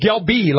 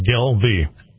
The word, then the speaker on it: Gelbvieh: It's Galbi, like Galbi. – Even though it's spelled with. G-E-L-B-V-I-E-H. – Gel, two syllables, Gel-V. – Gel-V, okay. – Gel-V. – You kind of forget the B in the middle or the B silent or something.